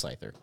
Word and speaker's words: Scyther: And it just so happens that there is a wild Comantis. – Scyther. You mispronounced Scyther.